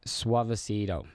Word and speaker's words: Suavecito: Suavecito.